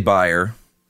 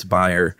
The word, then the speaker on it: Bayer: If you heard me say buyer and you're thinking, dude, it's Bayer aspirin. What are you, German? No, I'm not German, but that's right. How you pronounce it over there. It's Bayer. We pronounce it Bayer over here.